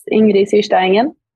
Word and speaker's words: Ingrid Syrstad Engen: I think it's Ingrid Syrstad Engen, we say in Norwegian. So I think Ingrid Engen is pretty easy in all countries. Maybe the second one is more complicated. – They might want to pronounce the D at the end.